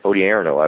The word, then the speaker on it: Odierno: And that puts, you know, General Mattis in charge of Afghanistan, General Ray Odierno. I'm sorry, I've got to say his name right. Odierno.